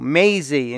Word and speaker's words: Mazie: Mazie, in case you didn't know how the uh, name is pronounced, it rhymes with not lazy, not hazy, Yes, the C word, crazy.